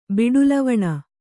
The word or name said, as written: ಬಿಡುಲವಣ